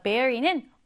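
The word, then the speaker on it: bury: 스펠링은 bury인데 읽을 때는 berry 이렇게 읽습니다. 자, berry는 묻다, 매장하다 이런 뜻이에요.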